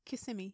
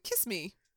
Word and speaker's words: Kissimmee: Kissimmee, yes. – I was like, kiss me. Kissy me. – It looks like it should be, but it's Kissimmee.